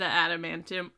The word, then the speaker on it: adamantium: Getting his the adamantium, is that, I can never pronounce that correct, like installed.